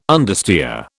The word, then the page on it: understeer: British English